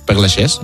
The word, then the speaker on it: Père Lachaise: No hay autopsia, fueron 5 personas solo al entierrero en el cementerio de Père Lachaise, creo que se pronuncia. Père Lachaise, merci.